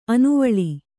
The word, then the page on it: ಆನೆವಾಲು: ānevālu